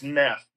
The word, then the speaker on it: Knef: It's spelled a few different ways, so I think it's Neff.